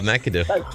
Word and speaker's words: McAdoo: McAdoo. – That's right. Bob McAdoo.